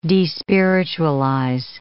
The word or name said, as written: despiritualize